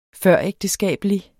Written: før

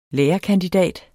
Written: lærerkandidat

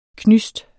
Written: knysts

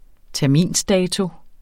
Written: terminsdato

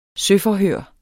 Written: søforhør

